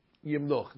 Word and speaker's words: yimloch: And therefore, as important as tune is, you really should try to keep the pronunciation of the words uh, correct. So melech, malach, yimloch.